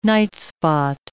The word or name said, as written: nightspot